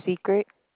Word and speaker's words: Secret: My name is Secret. – Could you spell that for me? – S-E-C-R-E-T.